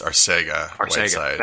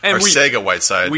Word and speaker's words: Arcega-Whiteside: And I want to correct you before anybody corrects you on Twitter, it's Arcega-Whiteside.